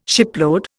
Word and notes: shipload: Indian

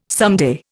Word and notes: someday: Indian

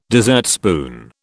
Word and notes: dessertspoon: British English

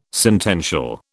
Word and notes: sentential: British English

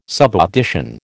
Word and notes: subaudition: American English

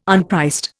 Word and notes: unpriced: Indian

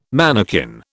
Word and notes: manakin: British English

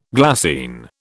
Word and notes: glassine: British English

